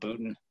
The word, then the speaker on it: Boonton: Well, it's spelled Boonton, but if you're there, you call it Booton.